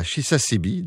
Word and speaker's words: Chisasibi: Et là, on va loin quand même dans le nord québécois, à Chisasibi, donc, tout près de la baie James.